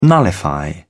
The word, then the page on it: nullify: British English